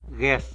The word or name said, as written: gues